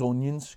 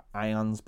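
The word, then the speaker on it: Ynyr: his name was Onions, because no one could pronounce his name. It's Y-N-Y-R-S or Irons, but everyone called him Onions.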